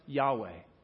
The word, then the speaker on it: Yahweh: In Hebrew, it's pronounced Yahweh. When we transliterated it into English, we pronounce it Jehovah.